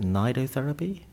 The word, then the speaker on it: nidotherapy: Is it nidotherapy? – That's right. It is a long I, because the treatment is named after nidus, the Latin for nest, and the notion behind that is that the nest is a very adaptable environment for whatever goes into it.